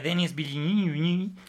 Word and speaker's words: Denis Villeneuve: Estamos hablando de Arrival, de Denis Villeneuve.